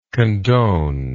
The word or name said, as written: condoner